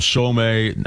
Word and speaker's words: Somme: Battle of Somme, that's World War One. I'm pronouncing that right, S-O-M-M-E.